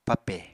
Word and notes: pape: Apatani

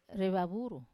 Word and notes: re vavuru: wamesa

